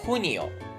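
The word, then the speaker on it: junio: 5 月マージョ、 6 月フニオ、このフっていうのがこう口の中の奥から出すようなフなんですけども、フっていう発音ですね。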